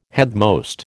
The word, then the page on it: headmost: American English